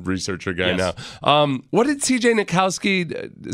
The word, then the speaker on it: Nikowski: How do you pronounce it? Nikitsky? What is his name? – CJ Nikowski? – Nikowski, yeah.